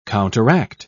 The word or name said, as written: counteract